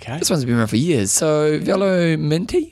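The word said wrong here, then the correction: Velominati: how do you say that one? – Velo Minati.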